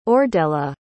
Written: ordella

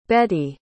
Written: Bettye

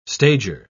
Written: stager

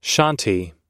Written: shanti

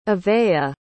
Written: avayah

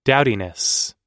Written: dowdiness

